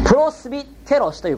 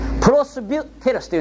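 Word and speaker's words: プ ロ ス ビュ テ ロス (πρεσβύτερος): プロスビテロスという言葉がある。オスというのはこれは人のことです。プロスビュテロスというんですけども、ビュという言葉を書くのめんどくさいからビと書いてきます。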